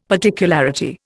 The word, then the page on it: particularity: Indian